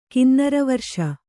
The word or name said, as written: ಕಿನ್ನರವರ್ಷ